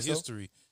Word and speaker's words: HSTRY: It's called History. H S T R Y.